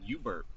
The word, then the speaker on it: UBRP: Yeah, so at the University of Arizona, which is where I got my undergrad degree, we had this program called UBERP. Well, that's what it's pronounced as. It's UBRP.